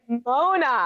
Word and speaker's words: Mona: And it's, okay, it's Moana, I'm, right? Is that how you say it? Mona. I'm oh, actually, Mona. It looks like Moana. Everyone in the audition thought it was Moana, but it's Mona because Mona is just, it's such a more it's such a it's such a queer lady name.